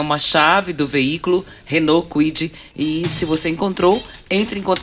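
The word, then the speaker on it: Kwid: Como é que é a pronúncia é? – Quid? Quid? Quid. Quid. Renault Quid. Isso.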